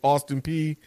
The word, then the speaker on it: Austin Peay: Austin Peay, and the way they took care of Nichols.